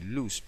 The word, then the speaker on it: Luz: Sino que es una palabra hebrea que se llama, se pronuncia así, luz.